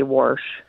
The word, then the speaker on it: wash: And I just wanted to make a comment about you with the way you pronounced wash. In Iowa, they say Warsh.